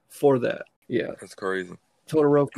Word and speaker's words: Todoroki: Todoroki.